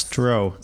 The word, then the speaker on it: Stroh: Stroh. – Stroh. – There's a beer in the U.S called Stroh's beer. Stroh.